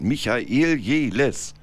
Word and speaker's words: Michael Jehles: Mein Name ist und bleibt Michael Jeles. – Mein Name ist und bleibt Uwe Ha. – Ja, ich musste das mal so aussprechen, weil die Leute alle denken, ich heiße Demichael Jeles.